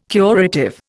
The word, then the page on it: curative: Indian